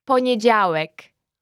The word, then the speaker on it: poniedziałek: Monday is poniedziałek, and on Monday is w poniedziałek.